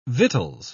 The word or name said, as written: victuals